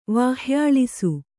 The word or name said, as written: ವಾಹ್ಯಾಳಿಸು